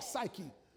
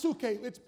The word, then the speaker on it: psuche: That word soul comes from the Greek word psuche. It looks like psyche. Pronounced psuche. It's the soul.